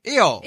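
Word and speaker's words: Io: That's me mispronouncing however I-O is pronounced. That was the chant of Saturnalia. You would just yell it out. – Eo.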